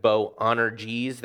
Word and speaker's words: Boanerges: To them he gave the name Boanerges, that's how you say it.